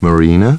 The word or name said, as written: Marina